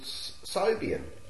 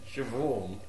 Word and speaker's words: Siobhan: Well, reminds me of a girl I went out with called Sobian. – Siobhan. – Why can't you spell it right?